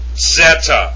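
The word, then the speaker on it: Zeta (ζῆτα): Next you have Delta, Epsilon, and this next one is not pronounced Z. It's pronounced Z with a D. Zeta. Zeta. It's not Zeta. It's like with a D. Zeta. Zeta. So mark that Z out of there and put a DZ.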